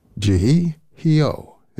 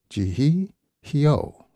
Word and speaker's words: Jihee Heo: Jihee Hyo is the name of the pianist. Her name is spelled J-I-H-E-E-H-E-O, Jihee Hyo, from her album Are You Ready, a tune there called Dancing in the Sorrow.